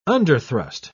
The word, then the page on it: underthrust: British English